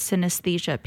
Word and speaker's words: synesthesia: And then the last session we did was synesthesia painting.